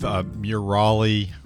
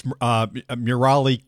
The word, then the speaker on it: Murali: That was a fellow by the name of Murali. Wait, am I pronouncing that at all correctly? No, I am not. And it's Murali Coryell.